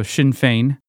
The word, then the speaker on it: Sinn Féin: It's called Sinn Féin. It doesn't look like that, but that's because we don't know how to pronounce Celtic words. So Sinn Féin.